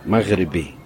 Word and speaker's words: Maghribi: Maghribi. That's how you say it in Arabic.